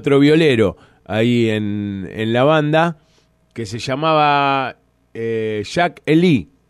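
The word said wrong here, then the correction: Jake E. Lee: Sí, Jake Ely. – Esa era la pronunciación.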